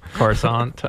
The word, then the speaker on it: Coruscant: Some planets, like Coruscant, for example, probably looks nothing like Coruscant looked. – Yeah. But Coruscant would be such a multicultural. – Is it Coruscant, Corazant, or Coruscant? – Coruscant.